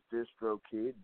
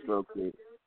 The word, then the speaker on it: DistroKid: Distro or Distro? You know, I botch up words all the time. – No, you're doing great. It is DistroKid, D-I-S-T-R-O, DistroKid. – DistroKid. Correct.